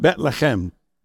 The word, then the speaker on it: Bethlehem: which is called Bethlehem," or if you're from Iraq, it's pronounced Bethlehem, all right? Because our Iraqi pastor, he corrected me on that many times.